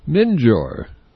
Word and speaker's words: Minjir: This next tune title, it's written by the leader, Michael League, and it looks like a typographical error, but I'll spell it for you, M-I-N-J-I-R. Not mentor, not minor, not monitor, minjor.